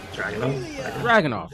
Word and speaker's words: Dragunov: Indeed, we are back, and we are starting off NXT Heatwave with Ilya Dragunov. Did I say that right? I feel like I always say it wrong. – Dragunov. – There you go. The ring announcer fixed it for me.